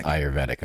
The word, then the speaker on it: Ayurvedic: It's Ayurvedic. – It's Ayurvedic, yeah.